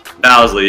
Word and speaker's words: Bousley: It's Bousley, though, right? – Bousley, yes, sir.